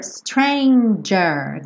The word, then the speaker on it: stranger: A stranger, stranger. Quem acompanha aquela série Stranger Things, é assim que se pronuncia, stranger. J, j, j. Letter G, né?